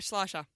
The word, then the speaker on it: Schleicher: Ruby Schleicher, Collingwood defender. Am I saying that right? – Yeah, Schleicher. – Schleicher, okay.